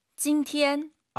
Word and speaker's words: 今天: 今天. – 일성 잘 하셔야 됩니다. 찐티엔 이게 아니라 찐티엔. 자, 해야 한다.